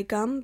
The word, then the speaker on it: Ghum: This next song is called Saturn by Gum, but it's spelled with an H, as in G-H-U-M.